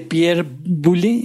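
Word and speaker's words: Pierre Boulle: Pero bueno, la original protagonizada por Charlton Heston y poca gente lo sabe, pero basada en la novela homónima de Pierre Boulez se pronuncia.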